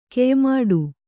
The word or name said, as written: ಕೆಯ್ಮಾಡು